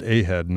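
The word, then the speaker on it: A-Hed: So it's pronounced A-Head, not odd. Although, hey, it could be odd, too.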